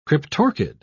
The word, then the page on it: cryptorchid: British English